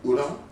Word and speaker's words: udon: Udon.